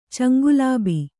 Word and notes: ಚಂಗುಲಾಬಿ: caŋgulābi

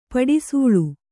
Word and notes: ಪಡಿಸೂಳು: paḍi sūḷu

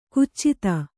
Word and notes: ಖಚಿತ: kannada

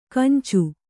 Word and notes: ಕೆಂಚು: Kannada

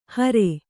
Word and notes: ಹಾರಿ: hāri

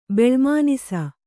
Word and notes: ಬೆಳ್ಮುನಿಸು: beḷmunisu

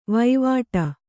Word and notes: ವೈವಾಟಿ: kannada

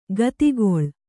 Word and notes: ಗತಿಗೊಳ್: gatigoḷ

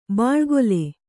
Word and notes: ಬಳಿಕೋಲು: baḷi kōlu; Kannada

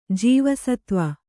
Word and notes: ಜೀವಸತ್ವ: jīva satva

